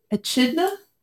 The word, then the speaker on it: echidna: It's called an echidna.